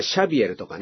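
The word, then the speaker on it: Xavier: じゃあ 400 年前に起こった出来事なんですね、うん、そもそもじゃあこのザビエルという人がですね、まあ、読み方によってはシャビエルとかね、まあカトリックの人なんかはですねシャビエルなんて言いますね。フランシスコ・シャビエル、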